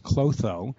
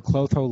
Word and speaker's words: Klotho: I'd like to present an article entitled Low, K-L-O-T-H-O, which I'm going to pronounce Clotho, levels relate to aging is associated with diminished ovarian reserve by Dr. Zi and Zhao from Beijing, China.